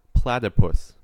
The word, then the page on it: platypus: American English